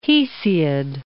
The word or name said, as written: Hesiod